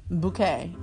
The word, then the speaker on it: Bucket: Bouquet. – Well, because their last name is Bucket. But the wife says it's pronounced bouquet. To everyone, and everyone always mispronounces it. And she has to correct them.